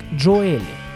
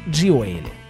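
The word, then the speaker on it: Gioeli: По-итальянски фамилия Джонни звучит как Джоэлли. В США же, где живет музыкант, его фамилия по-английски часто произносится как Джоэлли, в том числе самим музыкантам. В русскоязычной среде чаще всего встречается английский вариант. В интервью Джонни признался, что в его семье фамилию произносят совершенно по-разному. Его отец, как и он сам, произносит ее как Джоэлли, однако мать и старший брат говорят Джоэлли.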